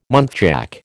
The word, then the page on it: muntjac: American English